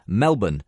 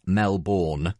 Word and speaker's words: Melbourne: Note, that's Melbourne, not Melbourne. Its pronunciation is one of the few things that Gus got right.